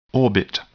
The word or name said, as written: orbit